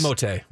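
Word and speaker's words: Timothé: Timotei is how you pronounce his first name.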